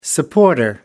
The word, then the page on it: supporter: American English